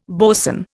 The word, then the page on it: boson: Indian